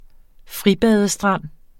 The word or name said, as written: fribadestrand